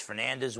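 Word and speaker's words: Fernandes: It's Fernandez with an S, not a Z.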